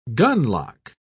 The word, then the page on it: gunlock: British English